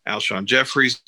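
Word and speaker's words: Alshon Jeffery: Alshon Jeffrey's back.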